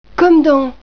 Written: comme

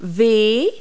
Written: w

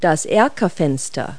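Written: bay window

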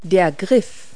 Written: hand tool

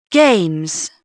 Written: games

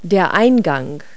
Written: entrance

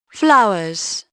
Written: flowers

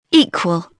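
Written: equal

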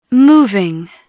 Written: moving

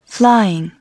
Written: flying

